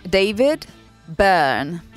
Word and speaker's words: David Byrne: David Byrne.